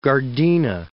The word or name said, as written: Gardena